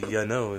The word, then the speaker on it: Yanow: It was founded in 2011 by Meg Gill and Tony Yanow. I believe that's how you say it. – Yanow. – It's actually Yanow. It's like Y-A-N-O-W.